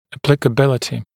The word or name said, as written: applicability